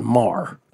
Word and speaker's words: Maher: By the way, it's Maher and Maher, but Bill's is Mar. That's how they pronounce it, right? – But it's M-A-H-E-R, and I don't think that the guy on VSiN is M-H-E-R.